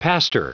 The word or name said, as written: pastor